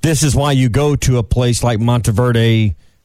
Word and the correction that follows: Montverde: Academy. I say that right, Montverde Academy.